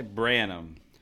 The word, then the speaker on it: Branham: Malachi Branham.